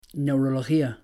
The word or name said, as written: Neurología